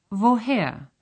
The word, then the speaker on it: woher: The first sound in these words is a V sound. – Was studieren Sie? – Und woher kommst du? – In German, the written letter W is pronounced like the English V in very.